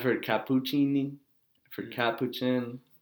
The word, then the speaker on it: Capuchin: So I'm a Capuchin Franciscan friar.